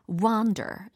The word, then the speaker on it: wander: w o n d e r이 아니고 W-A-N-D-E-R. 그러면 무슨 의미겠어요? 그죠. 돌아다니다, 다른 데로 가다 라는 의미죠. 그리고 발음도 약간 이렇게 좀 더 길어져요. wander 이렇게. 예, wonder이 아니라 wander 이렇게 체크하실 수 있겠죠?